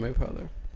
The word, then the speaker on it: Mapother: William Mapover? – Hell yeah. – Mapother? How do you say his name? – William Mapother.